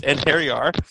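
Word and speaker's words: Einherjar: And I had to look that up because I didn't know how to pronounce that word. But it's Anhariar.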